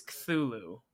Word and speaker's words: Cthulhu: I'm just saying, the pronunciation of Cthulhu is not supposed to be ka, it's Cthulhu, right? There is no noise in there, it's Cthulhu.